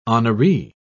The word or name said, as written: honoree